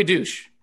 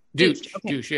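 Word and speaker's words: Deutch: First of all, I just have to admit that I had no idea that Zoe, how do you pronounce her last name? – Zoe Douche. Douche, yeah. – All right, okay. I just want to make sure before I didn't want to butcher that. – I believe how you say it, yes.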